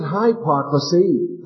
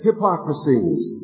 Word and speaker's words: hypocrisies: And I remember the late Mr. Frank Knox, he said, don't pronounce it hypocrisy. There's nothing high about it. It's low down. It's hypocrisies. And then envies and all evil speakings.